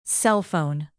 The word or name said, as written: cellphone